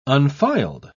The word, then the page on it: unfiled: British English